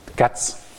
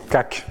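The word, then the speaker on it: Kac: Katz suivant la prononciation euh, polonaise, parce que je crois que j'ai toujours dit CAC, mais je crois que les Polonais, une fois j'ai fait cet exposé en Pologne, et les Polonais m'ont dit, euh, ça se dit Katz.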